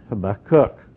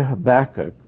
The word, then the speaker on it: Habakkuk: Now, a student of Hebrew will pronounce it Habakkuk, because in Hebrew, the emphasis is on the last syllable. But we'll just say Habakkuk for our sake, because that's what I'm accustomed to.